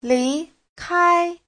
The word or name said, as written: li2kai1